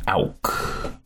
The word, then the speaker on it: Auck: And my name's Darren Auk. – Spell it. – D-A-R-R-E-N, and the last name is A-U-C-K.